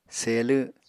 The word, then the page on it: selw: Apatani